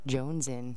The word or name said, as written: jonzen